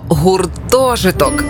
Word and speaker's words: гуртожиток: Як правильно гуртожиток чи гуртожиток? Наголос на перше О – гуртожиток.